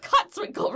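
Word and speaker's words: Kotzwinkle: I'm sorry, William Kotzwinkel. I mispronounced the first name. – Oh, dear. You got Kotzwinkel right.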